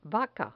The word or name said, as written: vaca